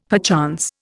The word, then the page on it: perchance: Indian